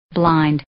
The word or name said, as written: blind